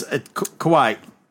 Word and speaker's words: kawaii: Yeah, it's kawaii. That was Kawaii. It's meant to be cute looking. – That's almost spelled like Hawaii, but. – It is actually like Hawaii.